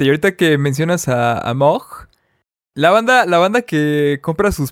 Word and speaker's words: Moog: Esa banda lo conoce como Moog, porque se escribe con doble O, Moog, es Moog.